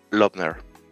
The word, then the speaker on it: Loebner: ¿Lobner? Lobner. Eh, Loebner se escribe.